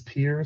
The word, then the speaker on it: Pierre: It is Pierre, South Dakota, pronounced correctly as well, our only monosyllabic state capital.